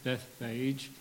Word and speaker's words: Bethphage: It all began at a little settlement called, I've always called it Bethphage, but in the Greek, the E at the end is pronounced, so it's probably Bethphage or Bethphage. Anyway, a little place that if you go to the Mount of Olives today to try and find it, there's nothing there.